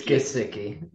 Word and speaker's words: Gesicki: It's Gesicki.